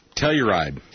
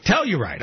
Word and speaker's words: Telluride: Telluride. – Telluride.